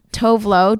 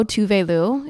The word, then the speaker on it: Tove Lo: Tove Lo, Tove Lo is the correct pronunciation.